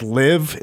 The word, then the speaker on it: LIV: It's a big one because the first event did start, and it's LIV.